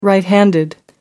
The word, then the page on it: right handed: American English